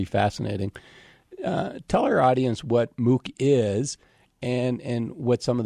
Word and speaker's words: MOOC: And there was an article that you have in there, is the term pronounced MOOC, the massive online MOOC? – Yes.